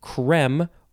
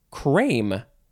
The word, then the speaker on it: crème: Okay, so this first word is, you can pronounce it creme or cream or crème. You could say crème if you want. Creme, cream, crème. It is spelled C-R-E-M-E,